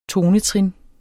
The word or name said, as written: tonetrin